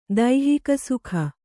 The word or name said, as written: ದೈಹಿಕಸುಖ